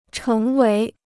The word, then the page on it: 成为: chéng wéi